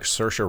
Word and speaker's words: Saoirse: Why does she look like Saoirse Ronan? – A little bit.